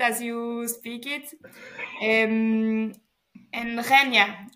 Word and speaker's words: Rennes: And before I say, when I was writing the questions, and I used to think the city, it's called Rennes, not René.